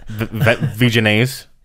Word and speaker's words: Vegenaise: Vegenaise.